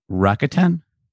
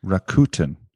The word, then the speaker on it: Rakuten: We've got, I'm going to say it wrong, Rakuten. – Sorry, Rakuten. I've been practicing and I still did it wrong. – Rakuten.